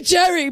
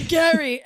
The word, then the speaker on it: Gerry: G-E-R-R-Y. – G-E-R-R-Y? This, like, no, you, how would you say that? – Gary. – I'd say Jerry, but they say Gary, and it's a really confusing name.